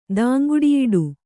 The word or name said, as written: ದಾಂಗುಡಿಯಿಡು